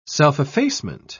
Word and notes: self-effacement: British English

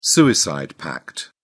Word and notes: suicide pact: British English

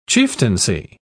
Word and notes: chieftaincy: British English

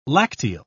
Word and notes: lacteal: British English

